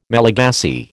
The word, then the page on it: Malagasy: American English